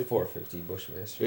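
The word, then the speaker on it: 450 Bushmaster: Four fifty Bushmaster.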